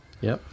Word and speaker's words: Garagiola: Oh, did I say that right? Garagiola. – Yep. Garagiola.